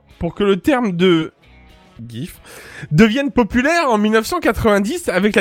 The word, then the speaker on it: GIF: Alors, euh, ça se prononce GIF, donc il y a un, semblant de D devant, donc GIF, voilà.